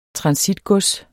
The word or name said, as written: transitgods